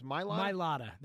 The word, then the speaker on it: Mailata: Mailata. – There's no Maya. It's Mailata. – I always call him Mailata. – I know you do. – So I should call him Mailata? – Mailata.